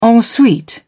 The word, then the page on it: en suite: British English